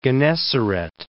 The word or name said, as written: Lake of Gennesaret